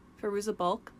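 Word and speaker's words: Fairuza Balk: Firuza Balk? – Yep.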